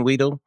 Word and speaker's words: Wheatle: is that the pronunciation Wheatle? yes absolutely Yes, his is the fourth story.